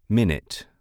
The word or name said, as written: minute